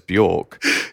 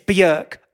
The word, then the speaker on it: Björk: She told you how to say the name Björk because everyone else says Björk. – Yeah, that's right. – What did she say to you? – She went, it's Björk, like jerk, and stared me in the eyes.